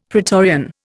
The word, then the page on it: pretorian: Indian